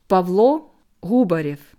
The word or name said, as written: Pawlo Hubarjew